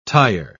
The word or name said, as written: tire